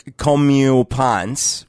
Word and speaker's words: comeuppance: Comeuppance, right? – And it sounds like come up.